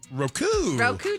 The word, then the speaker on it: Roku: But that's the first time you pronounce it correctly, because the emphasis you were putting on Roku channel. Roku.